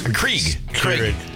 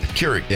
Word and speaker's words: Keurig: Krieg. – Keurig.